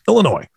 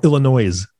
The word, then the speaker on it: Illinois: Next state on the list, Illinois. Or Illinois, for those that are wanting to pronounce the S.